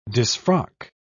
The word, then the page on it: disfrock: British English